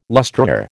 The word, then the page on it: lustreware: American English